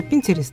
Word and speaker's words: Pinterest: Сегодня и Ксюша на тот момент, но сегодня уже вот этот сайт, правильно произносится, Pinterest.